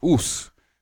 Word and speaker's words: ours: So I type in bear, and in French it punches out O-U-R-S, which is pronounced ous.